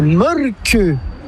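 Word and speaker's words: Mölkky: Ça s'écrit Molki, mais ça se prononce Molke. – Le Molki. – Plutôt un E qu'un E, mais pas un U. Même ça fait Molke.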